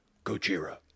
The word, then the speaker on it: Gojira: I also still laugh pretty hard in that scene, though, when it zooms in on Ken Watanabe's face and he does the Japanese pronunciation and he's just like, Gojira.